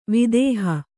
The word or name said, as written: ವಿದೇಹ